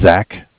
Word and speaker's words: Zach: My name is Zach, spelled Z-A-C-H. – Okay. – I'm calling with Bank of Cardiff. Cardiff is spelled C-A-R-D-I-F-F.